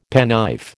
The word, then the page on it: penknife: American English